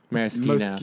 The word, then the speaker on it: Moschino: Maraschino. – Moschino. Moschino.